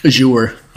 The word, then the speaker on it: Azure: Azure.